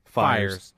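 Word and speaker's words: Fiers: David Ortiz came out and said, oh, so now talking about Fears? How do you say his name? – Fires. – Yeah, leave me alone.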